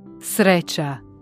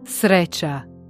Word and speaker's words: sreća: C-Akzent klingt weich, ähnlich wie das TCH in Brötchen. Ein Beispiel dafür ist Sreća, das Glück bedeutet. Hör gut hin. – Sreća. – Glück. – Sreća.